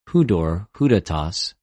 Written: ὑδωρ,ὑδατος

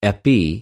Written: ἐπι

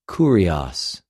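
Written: κυριος

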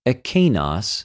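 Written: ἐκεινος

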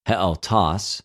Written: ἑαυτος

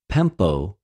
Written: πεμπω

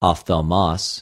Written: ὀφθαλμος

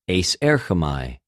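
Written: εἰσέρχομαι

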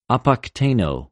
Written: ἀποκτεινω

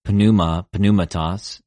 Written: πνευμα, πνευματος

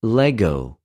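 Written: λεγω

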